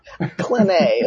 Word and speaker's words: Clenet: Clenet is my last name.